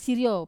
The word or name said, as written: sirio